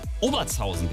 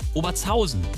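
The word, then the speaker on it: Obertshausen: Oberzhausen zum Beispiel, ne, ist ein Beispiel auch in meiner Heimat im Rhein-Main-Gebiet, wird oft Oberzhausen genannt. Also mit der Betonung auf der ersten Silbe. Es heißt aber Oberzhausen.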